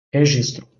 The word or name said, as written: Registro